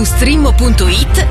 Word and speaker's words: Streammo: Vai su strimmo.it, si scrive Streammo o scarica l'app.